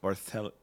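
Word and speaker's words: Barthello: I'm gonna say it with a decently hard T. Bartello. – You're gonna do it's Sicilian pronunciation.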